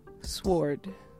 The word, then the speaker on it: sword: It's showing a lot of attention to a letter that's just there. – Okay, so and it don't make sense. Sword. – Sword? – Yeah, I mean if the word is pronounced word, if you put an S on it, it should be sword.